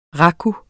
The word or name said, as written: raku